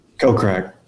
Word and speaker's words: Kokrak: Kokrak.